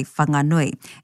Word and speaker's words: Whanganui: For those of us who don't live there or come from there, it's acceptable to say Whanganui, as the name is spelt with a wha. And other iwi do pronounce that WH in whanga.